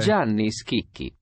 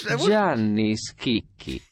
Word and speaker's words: Gianni Schicchi: All right, let's see if how we pronounce his name. – Okay. – Gianni Schicchi. – There you go. You're right. – I was Gianni Schicchi.